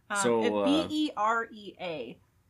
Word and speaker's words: Berea: It is not beret. Nope. looks, looks like it is Berea.